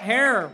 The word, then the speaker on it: Herer: We are at the strain, Jack Herer, rhymes with terror.